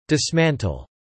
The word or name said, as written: dismantle